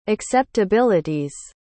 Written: acceptabilities

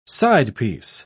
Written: sidepiece

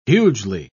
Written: hugely

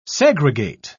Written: segregate

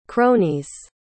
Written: cronies

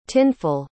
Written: tinful

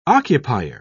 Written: occupier

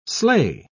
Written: slay

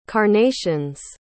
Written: carnations

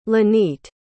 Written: lanete

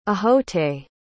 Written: ahote

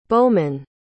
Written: bowmen

